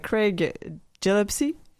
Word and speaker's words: Craig Gillespie: Craig Gillespie. Est-ce que je le dis comme il faut? – Euh, oui, oui, oui, oui. – Oui, oui. C'est comme ça que ça se prononce.